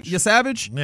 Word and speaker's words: Yesavage: The most interesting thing, I think, from this game was Trey, how do we say his Yesavage. – Last name? Yesavage? – Yeah.